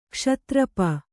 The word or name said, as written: ಕ್ಷಾತ್ರಪ